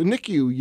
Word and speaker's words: NICU: We're going to talk about something that's really – close to your heart, and it's the NICU units.